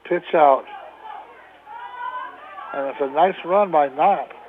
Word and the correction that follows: Kopp: Check that thing. Kopp, Tyler Kopp, if I get his name right.